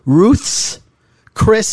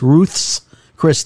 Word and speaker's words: Ruth's Chris: So in reverse order, on Friday night, so I don't know, 6 days ago, we went to, saying it properly, Ruth's Chris Steakhouse. – Correct. – That's Ruth apostrophe S. Ruth's Chris Steakhouse for my youngest daughter's birthday.